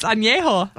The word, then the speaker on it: añejo: Is that how you pronounce it? I don't even know. – Yeah, it's añejo.